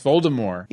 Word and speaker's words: Voldemort: Voldemort is actually pronounced Voldemort without a T.